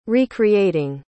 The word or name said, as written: recreating